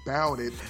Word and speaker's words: Bout It: It is because of Grambling that I learned that Bout It rhymes with Rowdy.